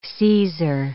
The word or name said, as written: Caesar